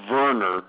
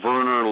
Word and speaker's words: Werner: Bjorn still complains to me that some of his teammates are idiots and calls him Bjorn Werner with a W. It's Bjorn Werner, like Werner, like the soda, Werner's. The W is a V, but I'm told that's the German pronunciation, the way he would want it pronounced in Germany.